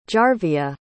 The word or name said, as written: jarvia